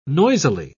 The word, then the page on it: noisily: British English